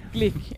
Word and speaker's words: Glück: Hon heter väl typ Glück? – Ja, det stavas Glück. Hon säger Glück.